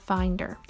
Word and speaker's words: Findyr: And the first one I'm going to talk about is called Finder. And it's spelled F-I-N-D-Y-R.